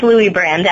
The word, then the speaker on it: Luibrand: It's Luibrand, actually. – Okay, Luibrand. Okay, because I did it the Dutch way.